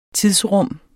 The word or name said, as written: tidsrum